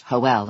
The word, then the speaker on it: Joel: Lucia's husband, Joel, pronounced Joel in the Spanish tradition,